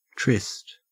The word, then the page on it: tryst: Australian English